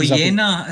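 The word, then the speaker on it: Nuyina: But anyway, the Nunia, is it Nunia? Nunia? Hang on a second. I've got a pronunciation. – Noyena, isn't it? – Noyena, that's the one.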